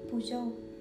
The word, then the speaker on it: Peugeot: Huyền thoại Puzo Khi nghe mình đọc thì chắc là một vài bạn sẽ không hình dung ra được cái từ mình mới đọc đúng không? Bởi vì khi mà mình tra cách đọc thì mình cũng khá là bất ngờ vì trước giờ mình toàn là đọc sai thôi